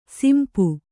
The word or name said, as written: ಸಂಪು